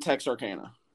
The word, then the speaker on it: Texarkana: So, you're an assistant coach at A&M Arcana? Am I saying that right? – A&M Texarkana.